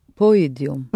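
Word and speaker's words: пойдём: Мы пойдем. – Mm-hmm. Пойдем. Accent the ending again.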